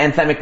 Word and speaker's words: anthemic: And it's just the kind of anthemic, is that how you say it? – Sure, absolutely. – Sure. Yeah. It's the kind of anthemic music that you can kind of just like pump your fist to.